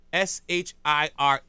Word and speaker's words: Worcestershire: I can't say this word. I can spell it. WORCESTERSHIRE. It's the Shire at the end that gets me. How do you say it? – Isn't it Worcestershire? – Is it? – I think it's Worcestershire.